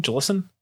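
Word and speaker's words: GLSEN: I think it's GLSN, I think, Jalison. That's how it's pronounced.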